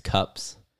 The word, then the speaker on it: Cupps: Because Nick Cups turns into Nick Ups. Like, it's just hard to say. It's two hard C's together. And so you have to pause Nick Cups.